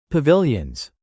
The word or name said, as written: pavilions